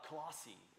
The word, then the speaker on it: Colossae: Colossae is how you say that town.